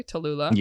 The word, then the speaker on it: Tallulah: Tallulah.